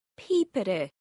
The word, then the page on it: Pìobaire: Scotland